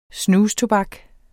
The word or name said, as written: snustobak